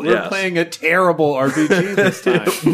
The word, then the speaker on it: Tearable: It is called The Tearable RPG. That's spelled T-E-A-R-A-B-L-E. Terrible as in to rip something apart.